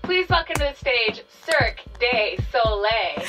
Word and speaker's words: Cirque du Soleil: He's in Cirque du Soleil. Is that how you say it? Cirque du Soleil? – But isn't it Cirque du Soleil? – Cirque du Soleil. That's how you're supposed to say yeah.